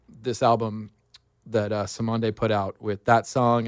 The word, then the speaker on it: Cymande: The band is Simonde. you'll recognize a couple of the tracks. I played two kind of lesser known ones. The Message is pretty famous, but yeah, it's spelled C-Y-M-A-N-D-E.